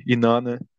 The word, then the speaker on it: Inanna: Aí fui lá, regravei, Inanna. É, Inicialmente tinha cantado né, né? Falei, pô, que isso, cara? Não pode ser isso, não pode ser essa pronúncia ali pro mais americana, tem que ser a pronúncia correta.